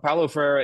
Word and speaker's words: Paulo Freire: And the guy that you talk about here is one that I wasn't even familiar with, a Brazilian named Paulo Freire, if I'm saying that right. – Yeah, that's right. Yeah, Paulo Freire is, and it's quite interesting because most people are not familiar with him.